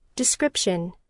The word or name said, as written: description